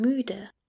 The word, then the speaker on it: müde: Ich bin müde. I'm tired. Ich bin müde. Ich bin müde. Remember how we pronounce U? The lips form an U, but the mouth tries to pronounce E.